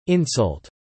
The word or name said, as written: insult